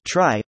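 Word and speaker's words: trie: How to implement tree, prefix tree, blind 75 LeetCode QUESTIONSA tree, pronounced as try, or prefix tree as a tree data structure used to efficiently store and retrieve keys in a dataset of strings.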